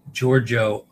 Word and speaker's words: Giorgio: Soft G sound as in Georgia. Giorgio Amani.